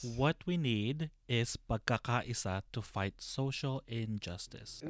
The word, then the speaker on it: pagkakaisa: Pagkakaisa. Is that correct? – Well, you are missing one more syllable. – What is it? – It's pagkakaisa. – Pagkakaisa.